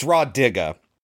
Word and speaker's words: Rah Digga: And Ra Diggs, you're not a good actress. I'm sorry, I'm totally getting her name wrong. It's Rah Digga.